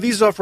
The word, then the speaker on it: Vizoff: Dorona Vizoff, Rhymes with Keep the Cheese Off,